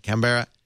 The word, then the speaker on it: Canberra: When I asked him, he said it, Canberra Grammar, because it's Canberra. You don't say the syllables. Like Melbourne is Melbourne.